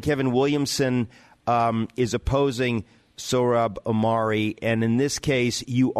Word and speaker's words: Sohrab Ahmari: You said that Sohrab Ahmari, is that how we say his name? – Yes.